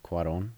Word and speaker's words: Cuarón: Yeah, the accent's over the O, so Cuarón would be how it looks.